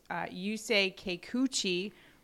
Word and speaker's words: Yusei Kikuchi: And the first one we're going to look at is Boston going up against Yusei Kikuchi for Toronto.